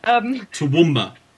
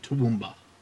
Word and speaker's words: Toowoomba: Toowoomba. It doesn't have an H on the end. There is no elongated A. It's a short A. Toowoomba. Not Toowoomba. Toowoomba.